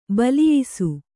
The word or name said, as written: ಬಲಿಯಿಸು